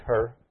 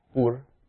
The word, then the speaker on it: Hur: So, Aaron, it's often pronounced her. Maybe I'll just pronounce it Hur, because then you won't know if I'm speaking about her or him.